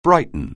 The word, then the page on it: brighten: anglais